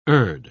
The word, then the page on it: urd: British English